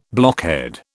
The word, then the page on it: blockhead: British English